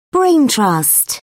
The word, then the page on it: brain trust: British English